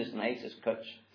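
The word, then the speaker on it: cwtch: Cutch. Is that how you say it? Cutch? – Yeah.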